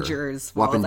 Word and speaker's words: Wappingers Falls: And Wappingers Falls, am I pronouncing that correctly? – Correct. – Wappingers Falls? Wappingers Falls or Wappinger? – Wappingers Falls.